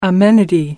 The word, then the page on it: amenity: American English